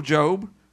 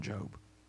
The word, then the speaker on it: Job: It's kind of like Job said in his story in the Bible, the book of Job. I like it because it's spelled like Job, and a lot of people don't even know how to say it, and they'll say Job, the book of Job.